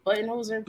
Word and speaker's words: Budenholzer: the Bucks ended up firing head coach Mike Budenholzer. Did I say that right? Budenholzer. Okay. Yeah.